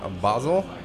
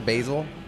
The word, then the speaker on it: Basel: Of the Upper Strata in from Basel. – Basel, Switzerland. – Basel, Switzerland. What's the other way people pronounce it? Basil? – No, that's what you put on pizzas and stuff.